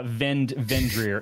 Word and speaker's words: Vindhyryr: Vendrir. It's spelled V-I-N-D. H-Y-R-Y-R.